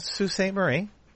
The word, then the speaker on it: Sault Ste. Marie: No, Sault Ste. Marie.